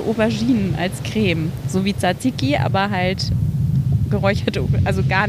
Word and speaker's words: Tzatziki: Beziehungsweise Tzatziki habe ich gelernt. – Du hast es gelernt. – So wird es ja ausgesprochen. Mhm. Tzatziki sagen wir Griechen.